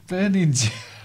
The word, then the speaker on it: Berninger: Berninger? – Berninger. – It's in my head. I can't stop. I'm pretty good at pronunciation, but I just can't get his name right. – Ger, not Ger.